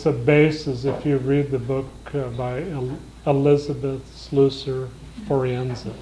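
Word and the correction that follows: Elisabeth Schüssler Fiorenza: Did I say that right? It's Schussler. Say it in German. – Elisabeth Schussler Forenza.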